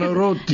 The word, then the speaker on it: Banaroti: Jessica Banaroti. Right? Did I say it right? – Banaroti. – Because I only know her maiden name.